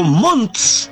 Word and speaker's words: Montt: Comúnmente, Puerto Montt viene del señor que se llamaba Ángel Montt. Y resulta que la gente de allá, para donde vaya, voy para Ángel Mo. No alcanzan a pronunciar la T final